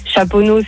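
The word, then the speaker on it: Chaponost: T'as Saint-Priest, c'est EST, tu le prononces. Chaponneau, c'est ST, tu le prononces pas.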